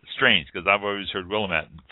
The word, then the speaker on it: Willamette: That is the Willamette. It's right. It's that. Not to interrupt you, Portia, but Willamette. You pronounce it. I've always heard, and I've always said Willamette, but it's Willamette.